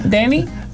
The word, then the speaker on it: Denis: Bueno, más adelante vamos a seguir con la entrevista que le hizo Steven Spielberg precisamente a este director Denis Villeneuve, un director canadiense. – Espérate, lo voy a escribir como se pronuncia. – La S okay. No suena. – Villeneuve. – No, que la S es de Denis.